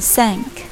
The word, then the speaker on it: thank: 读这个单词的时候，要轻轻咬一点舌尖，不要读成 thank，轻轻咬舌尖，thank，thank。